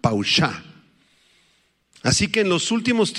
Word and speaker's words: pawshah: Paushah, la voy a deletrear, P-A-W-S-H-A-H, con acento en la segunda A, paushah.